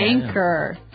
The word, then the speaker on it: anchor: Now, it's spelled A-N-C-H-O-R. – Right. – But you don't pronounce the C-H as you normally would, right? – Another one of these really difficult to spell English words. – Right. So you don't say anchor, you would say anchor.